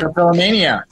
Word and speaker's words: trichotillomania: trichotillomania.